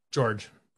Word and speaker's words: Georges: I do have to ask, how do you pronounce Mr. Niang's first name? – George. – Why is there an S? – Because it's fucking French, motherfucker.